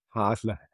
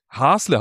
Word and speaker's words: Hasle: Und Haslach liegt im Kinzigtal, das ist auf Alemannisch, wie heißt das bei euch, Hässle? – Hasle. – Hasle heißt das, entschuldige bitte, die falsche Aussprache.